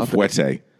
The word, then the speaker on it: fouetté: Until you've seen Ben Kingsley do a double fouetté.